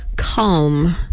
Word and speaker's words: Calm: And I feel like I have to really pronounce that Calm, as in like calm down, is an app you can go to for relaxation things to help you fall asleep.